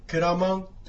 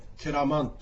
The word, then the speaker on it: ケラマン: でこのアイヌ語に対して私は分かりますという答え、これは猿川流域ですからケラマンというふうに発音いたします。これはもともとはクエラマンということが猿川流域ではくっつきましてケラマンというふうに発音します。